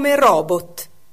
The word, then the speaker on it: robot: Robot o robot? Entrambe le pronunce sono ammesse, anche se l'etimologia della parola farebbe propendere per robot. Infatti, robot deriva dalla lingua ceca, in particolare dalla parola robota, che significa lavoro pesante o forzato.